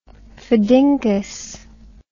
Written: phedinkus

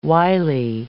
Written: wily

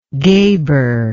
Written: gheber

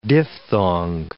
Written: diphthong

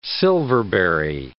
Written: silverberry

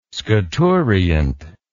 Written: scaturient

